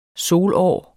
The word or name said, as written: solår